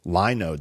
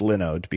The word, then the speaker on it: Linode: I pronounced it Linode the first time they sponsored because that's what it looks like, Linode. But of course it's pronounced Linode because it's Lin as in Linux.